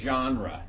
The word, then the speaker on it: genre: Okay, genre or genre. I don't know how to say this word properly. It's a French word. I hate the French language because I can't pronounce anything in it. Okay? But we generally say genre.